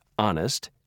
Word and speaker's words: honest: In a few words, like honest and hour, the H is never pronounced.